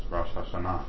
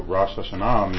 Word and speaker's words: Rosh Hashanah: In English, we might pronounce it Rosh Hashanah, but it's Rosh Hashanah. And so Rosh Hashanah means the head of days, because to the Jews, it represents, in the scripture, it's on the seventh month.